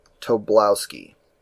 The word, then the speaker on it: Tobolowsky: Stephen, Tobolowsky, I think. Tobolowsky.